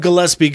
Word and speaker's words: Gillespie: I said Gillespie. – Oh, you suck. – Hard G. Doug Gillespie goes hard.